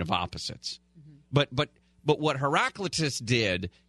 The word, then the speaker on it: Heraclitus: Heraclitus? How do you say it? – Heraclitus. – Right. You just added a syllable. – Well, yeah. – And obviously, it's a silent syllable.